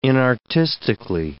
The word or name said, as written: inartistically